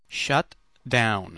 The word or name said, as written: shut down